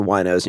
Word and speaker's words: wino: Its name is spelled W-I-N-O.